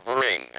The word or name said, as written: bring